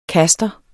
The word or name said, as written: kaster